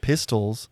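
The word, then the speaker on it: pistole: Forged French pistols, you know, counterfeit pistols. – Pistols was the name of their Spanish gold coin. They called them pistols, but it's spelled like pistoles or whatever.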